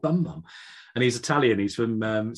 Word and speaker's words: Bumbum: He said, yeah, B-U-M-B-U-M, like the bum.